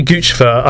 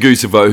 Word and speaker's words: Guccifer: Some people say Guccifer. I always say, like, Guccifer as in goose, and, like, I'm not going to change, even though I kind of know it's wrong.